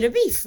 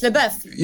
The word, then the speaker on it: LaBeouf: Why did they say LaBeef all the time? Ah, I didn't even realize it was a gag. I thought it was that they didn't know how to pronounce it. – Does he not introduce himself as LaBeouf, though? – I think he does. – Yeah. But it's the subtitles. It's like, no one's called LaBeouf. So, yeah, it's LaBeouf. – No, his name is his real name. In the credits, his name is LaBeouf. Like, you know, French spelling.